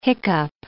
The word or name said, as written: hiccup